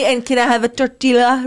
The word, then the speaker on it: tortilla: Oh, the L is silent. – I'm not saying tortilla.